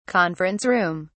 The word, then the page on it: conference room: British English